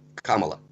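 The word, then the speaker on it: Kamala: Кстати, тоже, вот посмотрите, из-за того, что у нее такое странное происхождение, ударение на первом «а» – Камала.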